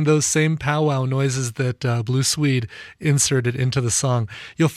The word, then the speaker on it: Blue Swede: Yes, originally by Cher covered there by Blue Suede, or I'm sorry, Blue Swede is how they pronounce that.